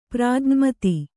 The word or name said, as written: ಪ್ರಾಜ್ಞಮತಿ